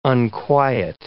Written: unquiet

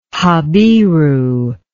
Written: Habiru

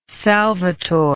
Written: salvatore

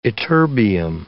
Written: ytterbium

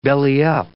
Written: belly-up

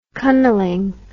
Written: cunnilingue

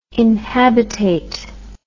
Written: inhabitate

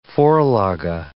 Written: vorlage

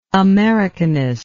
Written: americanistic

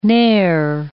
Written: ne'er

